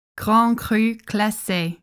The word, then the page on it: Grand Cru Classé: French